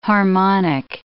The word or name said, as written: harmonic